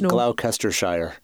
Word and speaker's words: Gloucestershire: I hate to correct you, I believe it's pronounced Gloucestershire.